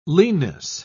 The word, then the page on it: leanness: British English